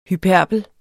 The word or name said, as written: hyperbel